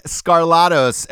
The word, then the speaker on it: Skarlatos: Ladies and gentlemen, we've got Alek Skarlatos. Pronounce it like Trebek. Trebek.